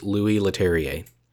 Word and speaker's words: Leterrier: I had no idea how to pronounce it. I looked it up and I completely forgot. So this time I made a point. I believe it's Louis Leterrier.